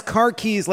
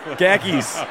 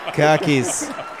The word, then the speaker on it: khakis: You pronounce car keys like it's a type of pant. – Khakis. – Khakis.